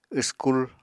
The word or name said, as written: wskul